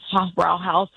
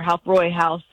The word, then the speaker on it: Hofbräuhaus: But the reality is, if you're going to Oktoberfest in Munich and you're sitting in a beer tent, you're in what we say in the U.S., Hofbrauhaus, or, you know, how they say it there, Hofbräuhaus, or you're in the Löwenbräu or the Löwenbräuhaus.